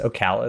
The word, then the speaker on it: Ocala: Ocala is how it's said.